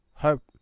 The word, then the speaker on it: herb: Wow, who don't pronounce the herb with no H, huh? It's so weird. Why spell it with an H? With a H. If you have a word with H in it, then therefore you must pronounce it like herb. Sorry, I just felt like it.